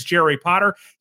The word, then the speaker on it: Jerry Potter: My name is Jerry Potter. Yes, it rhymes with the boy wizard,